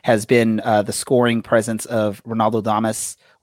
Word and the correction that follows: Damus: Can you can you share with us what's the correct way to say his last name? – Damus, you know? Ronaldo Damus.